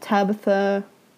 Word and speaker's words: Tabatha: Or on Facebook at Tabitha Whiteside. Yeah. And my name is spelled T-A-B-A-T-H-A. Whiteside is the color white. S-I-D-E.